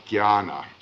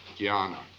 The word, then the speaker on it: Jnana: J-N-A-N-A, and it's pronounced Jnana, as though J-N were G-Y, Jnana. They put an enya, you know, that Spanish enya, over the first N there, Jnana. The religious one is bhakti, B-H-A-K-T-I. The action one is karma, K-A-R-M-A.